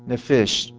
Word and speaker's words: nephesh: This is the Hebrew word nephesh. I'll spell it for you. N-E-P-H-E-S-H. Nephesh.